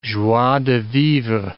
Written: joie de vivre

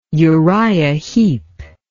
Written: Uriah Heep

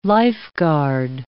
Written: lifeguard

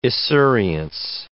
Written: esurience

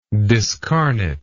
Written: discarnation

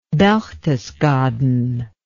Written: Berchtesgaden

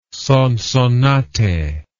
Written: sonsonate

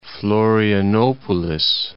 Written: Florianopolis

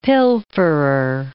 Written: pilferer

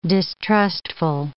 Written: distrustful

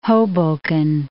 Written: Hoboken

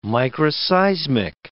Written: microseismic

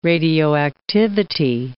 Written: radioactivity

